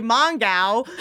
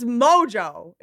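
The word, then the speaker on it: Mongeau: But I get panicked that I'm going to say Mongao. It's Mojo. It's even weirder. – Is it weirder. – Mojo? – Tana Mojo.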